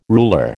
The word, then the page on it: ruler: American English